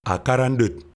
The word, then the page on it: Akarandot: LHHL